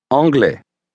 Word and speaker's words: anglais: Now say the same word, leaving off the L sound at the beginning. – Anglais.